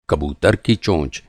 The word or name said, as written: चोंच